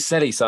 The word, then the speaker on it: Celis: Was that Chelis Jr.? – Yes. – Was that Antonio Chelis Jr.? Yes. – Antonio Celis, that guy. – Oh, is it Celis? I'm pronouncing that wrong. Celis. I do apologize.